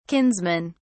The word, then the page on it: kinsmen: British English